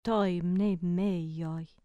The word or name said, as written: to mneimeio